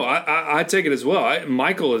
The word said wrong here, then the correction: Michal: is, how do you say her name? – Michal. – I've always said Michael.